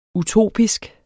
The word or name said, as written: utopisk